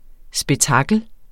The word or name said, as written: spektakel